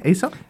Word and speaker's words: Aesop: Aesop, is that how you pronounce it? – Yeah, Aesop. Yeah.